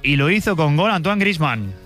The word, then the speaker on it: Antoine Griezmann: That is number 300 across international club games for that man, Antoine Griezmann. – Antoine Griezmann, lo pronuncian bien, la verdad. Obviamente a Antoine Griezmann no les cuesta mucho decirlo.